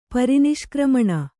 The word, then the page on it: ಪರಿನಿಷ್ಕ್ರಮಣ: pari niśkrmaṇa